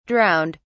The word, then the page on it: drowned: British English